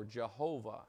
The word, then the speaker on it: Jehovah: and we get Yehovah, or Jehovah, as we call it, because we pronounce the J in English.